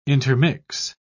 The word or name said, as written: intermix